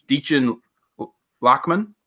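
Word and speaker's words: Dichen Lachman: So the girl was, I never say her name right. It's Deachin Lachman.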